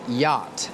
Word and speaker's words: yacht: The next word is yacht. This is a difficult word because it has two letters that are silent. The two letters are C and H.